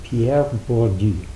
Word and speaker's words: Pierre Bourdieu: His name was Pierre Bourdieu. I think I got that one right. Yeah. Pierre, close. My mother would correct my pronunciation, but Pierre Bourdieu.